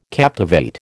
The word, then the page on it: captivate: American English